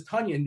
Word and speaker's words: Tunyon: And the reason why we know his name is Tunyon, because somebody tweeted out, it rhymes with Funyon, which, by the way, they could have used onion, but they said, let's go with Funyon, because that's more fun.